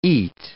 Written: eat

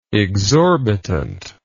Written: exorbitantly

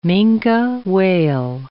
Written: minke whale

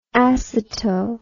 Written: aceto

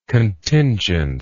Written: contingence

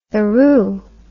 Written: theroux

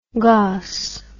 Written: goss

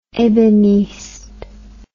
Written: ebeniste

